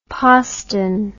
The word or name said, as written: poston